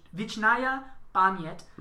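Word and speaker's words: Vichnaya Pamyat (Вечная память): And episode 5 is the only Russian self-titled episode, and it's pronounced Vichnaya Pamyat, which through our research this morning and translations, Ukrainian for eternal peace.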